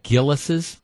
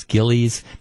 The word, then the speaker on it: Gilles: You've got Gillis's, and I understand it's, you know, a lot of pronounce, it's spelled G-I-L-L-E-S. And a lot of people pronounce it as Gillies. That's not right. It's Gillis is how you actually pronounce it.